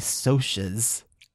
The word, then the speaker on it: Socs: They are the outsiders relative to the Socs, S-O-C-H, and that is the pronunciation given in the back of the book. – How's it spelled though in the book? – S-O-C-S. Did I say that? – S-O-C-H. – No, S-O-C-S. – So the socks. – It looks like socks, but it's pronounced Socs apparently. – Okay. – Short for socials.